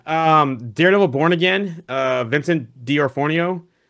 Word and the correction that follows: D'Onofrio: uh, D'Onofrio. I'm sorry, I keep, butch- I keep butchering his last name.